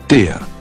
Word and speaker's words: der: The word rhymes with bear, so I think of a big male grizzly bear when I hear der Bär.